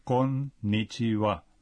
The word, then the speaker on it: konnichiwa: La pronunciación de la N en konnichiwa puede resultar difícil. – Sí. Para aprenderla, se puede separar la palabra en tres partes y marcar el ritmo. Konnichiwa.